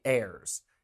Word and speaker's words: heirs: You and your sisters are my heirs. The H is silent.